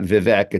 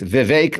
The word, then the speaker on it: Vivek: Before I have one more clip, this is Vivek Ramaswamy, and that's how you say his name. It's not Vivek, it's Vivek like cake.